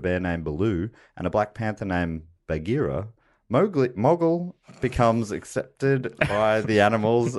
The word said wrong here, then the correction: Mowgli: It is Mowgli. I'm sorry. I was mispronouncing it that whole film.